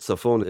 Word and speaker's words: Saphon: Yeah, Saphon is the word. Saphon is how you pronounce that.